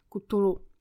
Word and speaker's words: Cthulhu: Jméno tady té nestvůry, eh, které dle Lovecrafta nezní jako nic z lidské řeči, má mnoho dalších variant a ta výslovnost eh, toho jména té nestvůry není jasná, ani Lovecraft to vlastně nikdy neuvedl na pravou míru, jak eh, by se to mělo vyslovovat, takže já pojmenovávám tak, jak eh, většina fanoušků u nás eh, to vlastně vyslovuje, takže Kutulu.